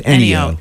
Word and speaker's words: Ennio: Is it Ennio or Ennio? – It's Ennio.